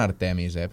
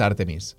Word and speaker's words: Artemis: Bueno, ells li diuen Artemis, però està mal pronunciat. – I el teu grup com és? – Artemis. És que en català és Artemis.